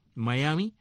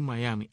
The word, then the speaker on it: Miami: No es Miami, es Miami. O sea, la M-I final la pronuncian como Mai.